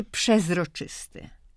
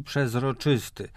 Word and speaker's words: przezroczysty: Od razu podam wyrok i tak, i przeźroczysty, i przezroczysty. W języku potocznym, ogólnym obie formy są mniej więcej jednakowo często spotykane.